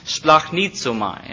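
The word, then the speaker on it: splachnizomai (σπλαγχνίζομαι): Again, roughly pronounced splachnizomai, and I'll spell it for you if you're taking notes. It's splachnizomai, splachnizomai.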